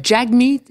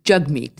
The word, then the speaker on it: Jagmeet: pas Jagmeet, c'est Jugmeet. – Exactement. C'est Jug comme hug en anglais. Jug, Jugmeet.